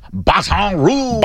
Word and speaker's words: Baton Rouge: I believe it's pronounced Baton Rouge.